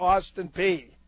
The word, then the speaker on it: Peay: Is it Austin Pay or Austin P? – It is Austin P. Even though it is spelled P-E-A-Y, it is Austin P.